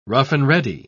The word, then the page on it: rough-and-ready: British English